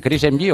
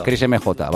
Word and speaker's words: Chris MJ: ¿Chris MG o cómo se dice? – MJ. – Chris MJ, vale, porque es, sí, porque sí, es chileno, vale, vale.